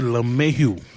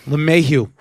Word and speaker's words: LeMahieu: DJ LeMahieu? Can't even pronounce his name. – Well, DJ LeMahieu. – LeMahieu.